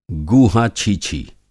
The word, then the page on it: गूहा-छीछी: Hindi